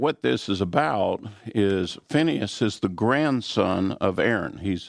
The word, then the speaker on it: Pinchas: This Sabbath, our portion is Phinehas. In the Hebrew, we pronounce it Pinchas.